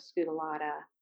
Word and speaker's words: scutellata: And they find that 90% of the workers that emerge have that Apis mellifera scutellata genetic. – I am not familiar with scutellata. Did I just say that right? – Yeah, I say it.